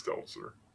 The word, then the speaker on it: Stelter: Is that how you say it? Stelter. Yeah, it's Stelter.